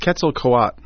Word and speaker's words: Quetzalcoatl: I think it's Quetzalcoatl.